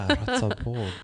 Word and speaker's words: Ratzeburg: Did I say it right, Ratzeburg? – Ratzeburg.